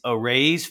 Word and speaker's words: Araize: It's Arayz Fast Fund Online. It's spelled A-R-A-I-Z-E.